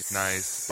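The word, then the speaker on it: gneiss: Nice baby. – Actually, it's a pronunciation that it's like almost too obvious to joke about.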